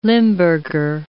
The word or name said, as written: Limburger